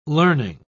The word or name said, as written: learning